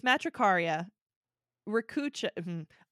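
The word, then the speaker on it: Matricaria recutita: Basically, it's Matricaria ricutata. R-E-C-U-T-I-T-A. See?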